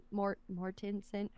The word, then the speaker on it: Mortensen: Mortensen. How do you say his last name? – I think you did great. – Yeah. – Thank you so much. – Yeah, you nailed it, Aragorn.